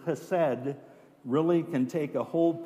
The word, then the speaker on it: chesed: Now, in the Old Testament Hebrew, the original word for steadfast love is chesed. And it's one of those words in Hebrew where, to say it properly, you need to spit a little bit.